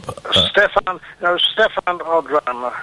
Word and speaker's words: Stéphane Audran: Stéphane Audran, who was one of the leading lights of French cinema, together with her second husband.